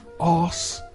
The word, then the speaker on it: arse: Well, I don't think they pronounce the R in arse. It's just os. You know, it's the usual English pronunciation where they just don't... They ignore the R's. The R's are silent.